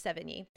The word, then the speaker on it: Sevigny: I always pronounce her name wrong, it's Sevigny, because I didn't hate,